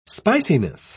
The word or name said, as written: spiciness